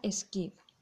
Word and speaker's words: SCID: Esta enfermedad es conocida como SCID, SCID por sus siglas en inglés y se pronuncia SCID.